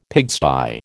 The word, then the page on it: pigsty: American English